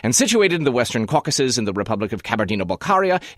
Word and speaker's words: Caucasus: As we mentioned earlier, the plural of a political caucus is caucuses, and that sounds just like the name of the mountain range. But the caucuses, as it refers to the mountain range, is spelled C-A-U-C-A-S-U-S.